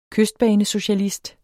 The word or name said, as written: KYSTBANESOCIALIST